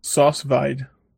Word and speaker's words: sous vide: But it's vide. – Sous vide. – Say it like a French chump. – Sous vide. – Yeah. – Sous vide. Yes. – Like yes, but less Russian.